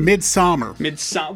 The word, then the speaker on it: Midsommar: Midsommar. – Well, I believe it's pronounced summer. It's just spelled a little bit differently.